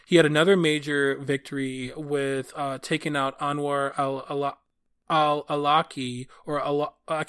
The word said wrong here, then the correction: Anwar al-Awlaki: can't even say his name, Anwar al-Awlaki, basically that was like the second in charge of Al-Qaeda under homeboy Osama bin Laden.